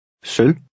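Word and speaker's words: sølv: Try number 7. Remember the silent V. – Søl.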